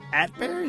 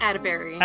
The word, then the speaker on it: Atteberry: Atteberry. – Atteberry. It was like a toss-up. – Yeah, only one person in my life has ever made the joke, like, attaboy, but Atteberry, like, Atteberry.